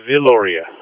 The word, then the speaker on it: Viloria: It's pronounced Viloria. It's phonetically spelled, so just V-I-L-O-R-I-A. Viloria.